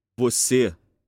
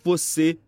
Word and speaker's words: Você: Você. Como você está? – Note the forceful sound of the word and try to match pronunciation.